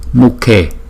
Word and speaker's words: Mukhe: Chữ K đứng một mình chúng ta đọc là K. Nhưng mà chữ K với chữ H đứng chung chúng ta đọc là K K K. Mukhe Mukhe Mukhe.